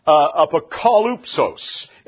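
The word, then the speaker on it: apokalypsis: And it's apokalupsos is actually the way it's pronounced.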